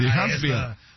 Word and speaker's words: Huntsville: Huntsville, City. ¿Sí lo dije bien, o no? – Sí, sí, sí. Huntsville.